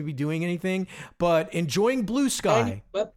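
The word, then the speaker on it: Bluesky: Or as I like to call it, Blue Ski, because it looks like it should rhyme with Brewski.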